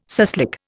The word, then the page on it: suslik: Indian